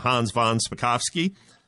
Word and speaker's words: Spakovsky: Well, we've been spending a little time with Hans von Spakovsky. I think I said that right three times, Hans. I'm, I'm very proud of myself. You, you get extra bonus points.